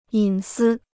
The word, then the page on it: 隐私: yǐn sī